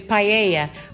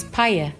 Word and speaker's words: paella: I used to thought it was paella, but it's not. It's paella.